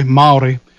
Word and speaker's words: Māori: That's how you pronounce it, by the way, Māori.